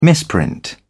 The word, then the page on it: misprint: British English